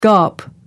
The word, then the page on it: gawp: American English